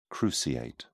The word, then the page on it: cruciate: British English